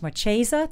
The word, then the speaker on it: Marchesa: She's a fashion designer, and her name is Marchesa. Did I say that correctly, Gary? – Yeah, you did.